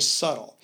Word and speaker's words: subtle: It has a B in it, but we don't pronounce it. – Mm-hmm. – He's very subtle.